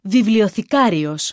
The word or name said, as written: βιβλιοθηκάριος